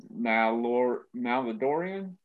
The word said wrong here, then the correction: Mandalorian: Mandalorian. They actually Mandalorian is not bad, because that was and I've heard that's really good.